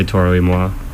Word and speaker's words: Toro y Moi: Toro E is Spanish, and then moi is French. – So, how do you say it? – I'd say Toro y Moi.